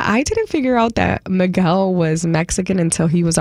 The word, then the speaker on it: Miguel: I know you guys say Miguel, and I know that that's how you say it in English, but it's Miguel, okay?